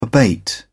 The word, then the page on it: abate: British English